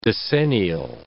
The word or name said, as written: decennial